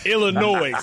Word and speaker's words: Illinois: Illinois. – America. – Listen, Illinois got an S on it.